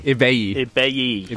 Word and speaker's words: Ibeyi: Yeah, to start with, we've got the new album by Ibai. Should I get right into it? – Ibai. – So that's Ibeyi. – I'm not familiar with three syllables.